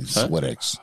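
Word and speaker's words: Smithwick's: I got Schmidtwix and Sons Ireland Red Ale. – You said it wrong. – Irish made. – Swidix. – I'm sorry. – Yeah. – Yeah. Swidix.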